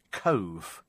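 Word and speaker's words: Cobh: Apparently, Cobe is pronounced Cove. Cove. So there you go. Apologies to everybody in Ireland.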